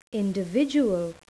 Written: individual

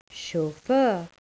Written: chauffeur

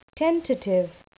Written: tentative

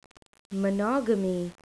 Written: monogamy

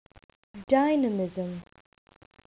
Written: dynamism

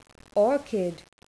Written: orchid

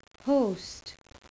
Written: host

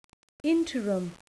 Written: interim

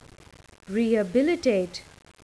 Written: rehabilitate

